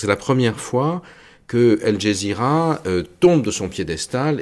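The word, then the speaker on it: Al Jazeera: contre la chaîne El Jazeera, qu'on prononce en arabe égyptien El Gezira, parce qu'on prononce le J G en Égypte,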